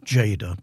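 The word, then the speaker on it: Tjader: That was Calcheda, and it's spelt in a very unusual way. It's pronounced Jada, but it's spelt with a T. T-J-A-D-E-R. Calcheda.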